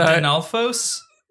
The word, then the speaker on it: Dinolfos: This is, I mean, you can pronounce it how you like, but you're mixing the L and the F in the wrong order. Like, you keep saying dinner floss. And so Dinolfos? – Yeah. – Dinolfos? – Okay. Dinolfos. Because they're dinosaurs. But they're like Stalfos.